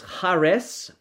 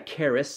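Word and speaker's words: charis (χάρις): Well, the word grace is charis. It looks like charis, C-H-A-R-I-S. Charis is how it's kind of pronounced there.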